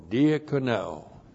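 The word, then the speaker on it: diakoneo (διακονέω): D-I-A-K-O-N-E, long O, diakoneo.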